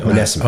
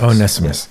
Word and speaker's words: Onesimus: Onesimus. – Onesimus.